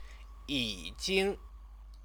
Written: yijing

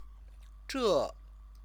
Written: zhe

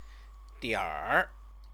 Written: dianr

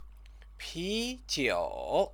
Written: pijiu

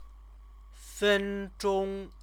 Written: fenzhong

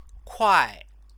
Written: kuai

